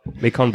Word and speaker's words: Macon: And then we have Macon Blair. Hopefully I'm pronouncing that right. – Macon Blair? – Macon? – Yeah. – Macon? I don't know. – It's pronounced Bacon.